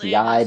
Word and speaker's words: Yi: And it's Yai, but it's spelled Y-I. Not YAI, so I don't know.